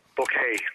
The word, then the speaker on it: Bucket: Hyacinth in the British sitcom Keeping Up Appearances spelled her name B-U-C-K-E-T, but how do you pronounce it? – Bouquet.